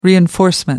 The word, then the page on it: reinforcement: American English